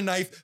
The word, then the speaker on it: knife: You want to call it a knife? Just call it a knife. Fuck the K.